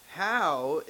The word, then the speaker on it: Hau: Well, it says How is typical. HAU is how his name is spelled.